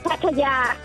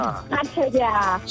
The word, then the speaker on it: Pattaya: The correct pronunciation is Pattaya. – Hang on, can she say Pattaya. That again? – Pattaya. – So there's a long Pattaya. Ya at the end.